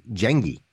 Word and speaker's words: Jengi: It's easy, man. It's it's Swedish uh, for gang, so it translates to gang is Jengi.